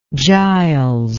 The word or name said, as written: Giles